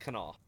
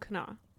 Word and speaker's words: Kanawha: Some people say Kanawha. Like, Kanawha, like that little. Okay. But, oh, we're at, like, the place I grew up saying, like, old, like, Kanawha. Kanawha is how you say Old Kanawha.